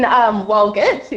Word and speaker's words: Walgett: She's in, is it Walgett? Is that how you pronounce it? Walgott in New South Wales? Heavens to Betsy. Hi. – Hello, ladies. – Hello. – What's your question for Saab from New South Wales? – Yeah, I'm out here in Walgett, is how you say it.